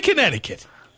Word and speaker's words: Connecticut: Going to Connecticut. That silent C gets me every time.